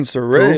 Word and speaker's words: Saraya: Oh, Google says it's Saraya. – That's fine. – Well, we were saying Saraya. Google? Yeah, like, you know, like, did how to pronounce things. – But did you get like an actual YouTube video of someone saying it? – Yeah, it was Saraya.